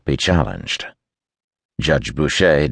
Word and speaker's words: Boucher: Boucher. Most people, seeing before hearing it, mispronounced it Butcher.